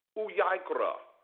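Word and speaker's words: Vayikra: Uyaikra is for Leviticus.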